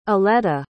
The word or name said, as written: eleta